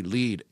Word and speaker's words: Lied: It's got to be Lead. It's spelled L-I-E-D, which would be an unfortunate thing because it looks like lied.